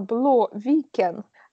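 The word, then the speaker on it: Blåviken: это сильно похоже на Бло Викен. Э, Бло это пишется Б Л А с таким кружочком над буквой, поэтому оно О.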